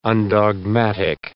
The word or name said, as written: undogmatic